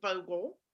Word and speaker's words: Vogtle: The T is silent. It's pronounced Vogtle.